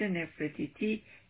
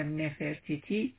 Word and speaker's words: نفرتیتی: از جمله در این مجسمه وسط که سردیس نفرتیتی یا نفرتیتی تلفظ هر دو جور تلفظ میشه، هم سر اخناتون هست که این سردیس خیلی واقع‌گرا و جاندار به نظر میاد.